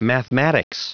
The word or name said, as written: mathematics